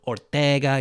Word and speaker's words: Ortega: And yes, I know they're bringing back Michael Keaton and Winona Ryder, and then they cast Jenna Ortega, you know, Ortega, you got to say it like that.